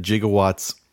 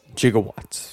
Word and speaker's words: gigawatts: So apparently I didn't know this either, that gigawatts and gigawatts are both acceptable pronunciations of the word. Apparently it is gigawatts, but scientists, and that was something that Christopher Lloyd had researched, scientists prefer to use gigawatts.